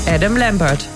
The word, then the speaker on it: Adam Lambert: Adam Lambert.